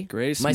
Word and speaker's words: Grace Mumm: Grace Mumm in the house today. – Grace Mumm.